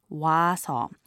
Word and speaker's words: 와서: 가 is a subject marker, 오다 means to come, and when it's put together with 해서, meaning because, it is pronounced as 와서.